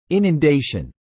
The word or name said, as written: inundation